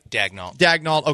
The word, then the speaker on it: Daigneault: Daigneault, am I saying it right? – Daigneault, okay, for the Thunder at plus 1,600.